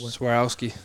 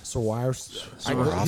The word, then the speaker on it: Swarovski: And he even bought those new Swarovski or so. How do you say it? – Swarovski. – Swarovski.